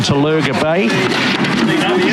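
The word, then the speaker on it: Tolaga Bay: We've been told there's the correct pronunciation. Apparently a lovely part of the world in New Zealand, Telurga Bay.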